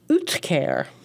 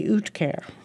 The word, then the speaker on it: uhtceare: It's spelled weirdly, too. U-H-T, not U-T-H, but U-H-T-C-E-A-R-E, Utcare.